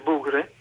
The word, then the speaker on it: Bogri: Beh, si intitola, in realtà è scritto Bogri ma si legge Bugre,